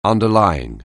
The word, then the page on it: underlying: anglais